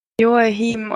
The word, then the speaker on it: Joaquin: Ui, achso, da wollte ich übrigens noch sagen, dass ich oder wir den Namen immer alle falsch aussprechen. Einmal Joaquin Phoenix, der heißt, ähm, also, also er lässt sich, glaube ich, Joachim oder so aussprechen.